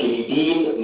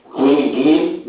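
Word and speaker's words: quinidine: يتسمى كينيدين لايك اكشن او في ناس بتنطقه كوينيدين لايك اكشن.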